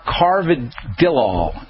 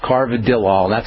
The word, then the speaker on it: carvedilol: It's C-A-R. – Uh-huh. – B-E-D-I-L-O-L. – It's a beta blocker. – Carvedilol is what it's called. Carvedilol.